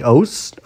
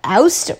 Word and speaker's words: Oast: So Sean had gotten this really awesome rhubarb beer from this local Niagara brewery called Oust, or if I'm pronouncing it wrong, O-A-S-T.